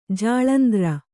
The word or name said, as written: ಜಾಲಂದ್ರ